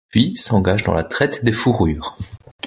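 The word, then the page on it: fourrures: french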